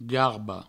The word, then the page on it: garba: garbo